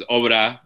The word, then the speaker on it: Obra: I mean, like it's a Spanish word, so like Obra in English is good. Yeah. Like the Spanish way to say it is Obra, but it's like the same thing.